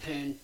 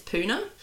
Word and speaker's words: Poona: I need to get back to Poon. – I need to, the Poon ashram. I think it, see, you've done what I did. I think I checked how it's actually pronounced and I think it's Poona.